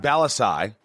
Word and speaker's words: Bellassai: I'm with Matt Bellassai.